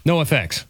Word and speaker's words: NOFX: NOFX.